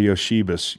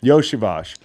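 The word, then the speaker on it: Iosivas: The number one hero in this game, and possibly for the Bengals on their entire season, is this Princeton guy, Andre Yoshibas. Is that how you say it? – Yoshivash.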